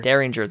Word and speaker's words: Derringer: Derringer. – Derringer.